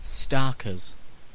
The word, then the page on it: starkers: /ˈstɑːkəz/; /ˈstɑrkɚz/